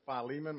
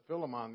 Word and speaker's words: Philemon: I've pronounced it Philemon my whole life, and I heard John pronounce it Philemon the other day, and I thought I may have learned a bad pronunciation years ago and didn't know it and just kept repeating it. And so I looked it up online, and that is what happened. I have been pronouncing it incorrectly for probably 50 years.